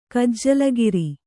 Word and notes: ಗಜಾಲುಗಾರ: gajālugāra

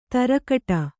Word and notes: ತರಕಟ: tarakaṭa

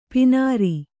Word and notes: ಪೀನಾರಿ: pīnāri

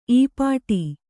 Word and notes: ಈಪಾಟಿ: īpāṭi

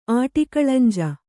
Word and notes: ಆಟಿಕಳಂಜೆ: āṭikaḷañje

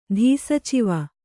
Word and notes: ಧೀಸಚಿವ: kannada

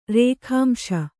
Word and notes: ರೇಖಾಂಶ: rēkhāmśa

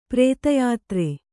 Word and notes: ಪ್ರತ್ಯುತ್ತರ: pratyuttara

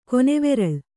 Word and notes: ಕೊನೆವೆರಲು: kannada

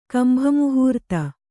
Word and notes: ಕಂಭಮುಹೂರ್ತ: kannada

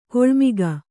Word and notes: ಕಿಳ್ಮೊಗ: Kannada

